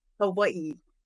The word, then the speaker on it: Hawaii: Hawaii, because I was Hawaii? – corrected about 10,000 times when I lived there. And they're like, "That's not how you say it."